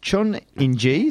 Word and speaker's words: Chun Inji: Chun Inji, is that how you pronounce her name? – Yeah.